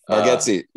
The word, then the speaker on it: Bargatze: Nashville guy Nate Bargatze, I think is how you say his name. – Bargatze, yeah.